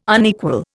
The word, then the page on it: unequal: Indian